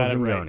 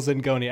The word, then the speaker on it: Zingone: Michael Zingone. – Right. Michael Zingone at FF Zinger on Twitter.